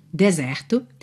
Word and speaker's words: desert: Se escreve D-E-S-E-R-T. Alright? D-E-S-E-R-T. A sílaba tônica é a segunda, então desert, desert. E esse S tem som de Z, right? Desert.